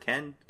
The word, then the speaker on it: Cannes: Ken? C-A-N-N-E-S? – Yeah, that's fine.